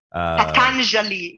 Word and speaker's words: Patanjali: Patanjali, yeah.